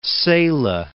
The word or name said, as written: Cela